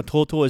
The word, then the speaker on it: とうとう: Now, TouTou is two long O sounds that is used with the TO sound and the OO sound after to make that long sound.